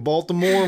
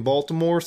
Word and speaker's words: Baltimore: Oh, I'm from Baltimore, and we don't really read in Baltimore, so you got any zines that ain't got no words in them? Excuse me, if you were really from Baltimore, you wouldn't pronounce the T.